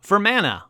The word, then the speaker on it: Fermanagh: And I'm going to pause right here to figure out how the fuck to say Fermagnach. That's right, Stitch. You get mad at me because I pronounced it wrong. Yep. Here we go. How to say it. Hey. – Fermanagh. – Well, yeah, I was way off.